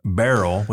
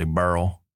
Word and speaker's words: Beryl: and they would say Beryl. – Beryl. – Beryl. – Beryl. – So it was like one syllable instead of two. It wasn't Beryl. It was Beryl.